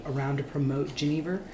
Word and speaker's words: Genever: And there's some discussion of how it's pronounced. Genever, Genever. Genever sounds right. – I've been correcting people all day long on this. And it's only because I went to this little class that I know how to pronounce it. Because it doesn't look like Genever.